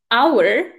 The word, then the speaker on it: hour: Takže například u slovíčka hodina, hour, i přesto, že se to píše h o u r, ale vyslovuje se jako první a, jako samohláska hour, tak řeknu n Hour.